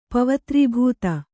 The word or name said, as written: ಪವತ್ರೀಭೂತ